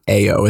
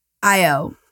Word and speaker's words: Ayo: But her name is spelled A-Y-O, right? Yeah, but it's definitely not A-O, is it? – No, I think it's pronounced I-O, yeah. I love her. – I-O.